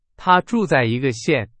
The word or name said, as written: 县